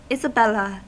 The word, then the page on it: isabella: American English